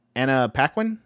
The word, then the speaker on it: Paquin: She is his wife, and Anna Paquin. Isn't that how you say her name? Right. – Yeah, that's right.